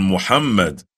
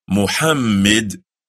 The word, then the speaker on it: Muhammad: O Allah, send prayers on Muhammad and on the family of Muhammad as you sent prayers on Ibrahim and on the family of Ibrahim.